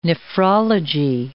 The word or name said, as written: nephrology